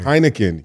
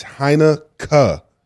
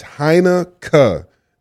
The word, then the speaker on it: Heineken: Heineken, you're saying it wrong. It's Heineken. It's Heineken. That K-E-N is pronounced K in Holland.